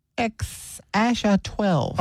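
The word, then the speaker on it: X Æ A-12: It says it's pronounced X. Asha 12.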